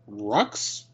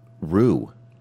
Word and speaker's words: Roux: Rux, R-O-U-X. – I'm questioning if it's Rux, Row. I think it might be. Let's see if I can figure out a correct pronunciation of how you would say that. Row, P-R-O, pronunciation. Okay. Rue. – Rue. – Rue. – Interesting. – Like Jumperoo? – Yeah, I mean, this is like smart.